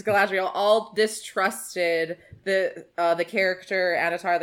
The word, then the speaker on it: Galadriel: It was Don't forget that it's pronounced Galadriel. – Galadriel.